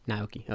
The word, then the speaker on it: Naoki: Naoki.